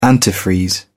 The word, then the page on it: antifreeze: British English